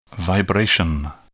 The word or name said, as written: vibration